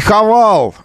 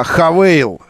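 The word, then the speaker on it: Haval: Еще что-то там еще было, не Хавал, китайский бренд, выпускающий кроссоверы, а Хавейл. Пишется Хавал, но все равно произносится Хавейл.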